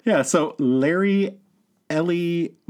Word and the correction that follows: Eli: Murillo. – Eli? – It could be Ellie. – It's Eli. – All right, fine. Larry Eli Murillo.